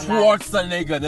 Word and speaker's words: Schwarzenegger: I don't know how to pronounce his surname. – Schwarzenegger. – I should be embarrassed because he's been in our Schwarzenegger. That's how he fucking pronounces his name.